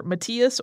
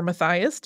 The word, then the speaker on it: Matthias: During this time, a Jewish priestly family led by Patriarch Mattatyahu, or Matthias or Matthias, depending on how you pronounce it, was living in Modain, west of Jerusalem.